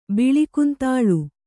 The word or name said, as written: ಬಿಳಿಕುಂತಾಳು